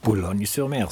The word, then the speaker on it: Boulogne-sur-Mer: Habe ich das richtig ausgesprochen? – Boulogne-sur-Mer, super. – Wie der Franzose so sagt halt, ne?